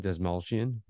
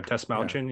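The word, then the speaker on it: Dastmalchian: How do you say his last name? Desmalchian. – Yeah, Desmalchian. Yeah.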